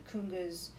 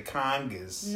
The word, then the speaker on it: conga: I like the way you say kungas. – Why am I saying it wrong? – No, you say it right. I taught you the right way. Huh. I'm impressed. All right, continue. – You taught me. – Yeah. An Italian from Scarsdale is going to say congas.